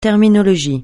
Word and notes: terminologie: francais

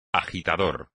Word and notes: agitador: espagnol

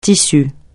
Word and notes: tissu: francais

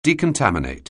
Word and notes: decontaminate: anglais

